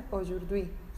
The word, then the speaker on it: aujourd'hui: pero ellos ponen A-U-J-O-U-R-D apóstrofe H-U-I. Fijaros, para escribir nuestro hoy, todas las letras que ponen se pronuncia aujourd'hui, hoy. Y mañana se dice demain, e, D-E-M-A-I-N,